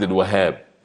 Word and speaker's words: الوهاب: الوهاب في شدة. عايز تكتبها باللغة الإنجليزية دبل اتش اتش اتش الوهاب هي كده. بالعربي الهاء عليها ايه؟ عليها شدة. ولما ننطقها لازم نقول عبد الوهاب مش عبد الوهاب.